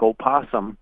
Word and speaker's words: opossum: But, yeah, it is spelled with an O, and a lot of people will pronounce the O, so it comes out to something like opossum.